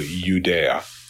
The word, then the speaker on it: Judea: It's that consonant I, which is pronounced like a Y. Okay, so Judea, Judea.